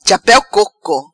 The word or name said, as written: Chapeu Coco